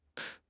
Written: 重心

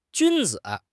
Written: 君子

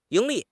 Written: 盈利

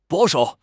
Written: 保守

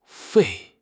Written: 肺

